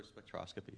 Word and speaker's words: spectroscopy: X-ray spectroscopy, yes.